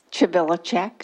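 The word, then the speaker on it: Chvilicek: Chvilacek. – Chvilacek. – Okay. C-H-V as in Victor. I-L-I-C-E-K.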